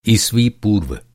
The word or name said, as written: ईसवी